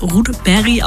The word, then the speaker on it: Rudberg: Eigentlich spricht man seinen Nachnamen wohl eher Rudberg aus.